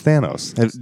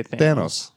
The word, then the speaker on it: Thanos: No, it's Thanos. Did you watch Avengers Infinity War? – They say Thanos.